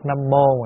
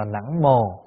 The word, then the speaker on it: Nam mô: Nhưng mà hồi xưa không có đọc nam mô, mà ngày xưa đọc là nẵng mồ. Sau này mình mới đọc, nó có hai cách đọc. Một là đọc nẵng mồ, hai là đọc nam mô. Nhưng mà nẵng mồ là ngày xưa ta đọc đó.